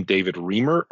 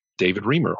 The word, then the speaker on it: David Riemer: They can either look up my name, David Riemer, R-I-E-M-E-R, or just Get Your Startup Story Straight.